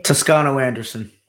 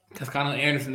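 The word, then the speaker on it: Toscano-Anderson: Uh, Tusca. Um, Toscano Anderson. – Toscano Anderson.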